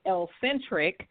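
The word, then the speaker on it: ElleXentric: My stage name performing is Elle-centric, which is a spin off of eccentric, and that is spelled ELLE, capital X, ENTRIC.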